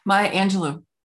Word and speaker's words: Angelou: Maya Angelou.